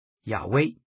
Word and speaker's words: 亚威: 近来的考古发现显示这个词应该发音为亚威，亚威最常被翻译成耶和华，但实际上应该是动词 Haya 的第三人称形式，可以被翻译成他是或是他将是。